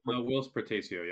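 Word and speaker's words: Whilce Portacio: Willis Portacio? – Willis Portacio, yeah.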